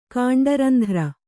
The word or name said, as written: ಕಾಂಡರಂಧ್ರ